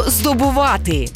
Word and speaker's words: здобувати: Сьогодні передача про слово «здобувати». І наголос в цьому слові ми ставимо на останньому складі.